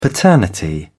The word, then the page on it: paternity: British English